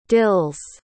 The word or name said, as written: dills